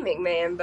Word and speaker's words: McMahon: Vince McMahon. – No. It might be McMahon, but it's spelled different. M-C-M-A-H-O-N. – McMahon.